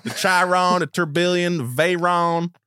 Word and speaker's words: Tourbillon: You're really pronouncing Tourbillon. That really well. – Tourbillon.